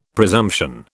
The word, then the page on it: presumption: British English